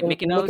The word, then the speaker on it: Mykonos: Mykonos.